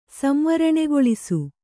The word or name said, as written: ಸಂವರಣೆಗೊಳಿಸು